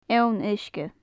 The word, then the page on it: eoin-uisge: gaelic